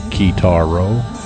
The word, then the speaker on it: Kitaro: Here in the West, I guess you'd pronounce it Kitaro.